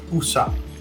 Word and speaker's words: Usopp: I just want to let y'all know it's pronounced Usopp.